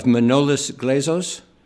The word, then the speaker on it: Manolis Glezos: But I would like for you to tell us the story and tell me if I'm pronouncing his name correct, of Manolis Glezos. – You've got it right.